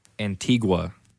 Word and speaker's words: Antigua: Yes, today we are drinking a Guatemalan Antiquo. I cannot pronounce this. – You got it at an antique shop. – Antigua. – Oh. – Guatemala Antigua.